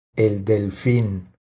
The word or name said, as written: El delfin